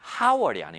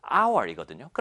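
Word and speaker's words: hour: 여기 있는 hour이 발음이 how월이 아닙니다. h가 있다고 해서 how월이 아니라 hour이거든요. 그러면 h는 사실은 무음, silent, 소리가 나지 않는 거예요.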